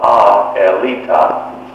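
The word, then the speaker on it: Aelita: This was a Russian film made in approximately 1923, which I had been mispronouncing all my life until I finally found it's called A Elita.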